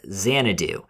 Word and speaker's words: Xanadu: Well, I did mispronounce the Citizen Kane house. It's Xanadu, which is also, you know, of course, set in Florida.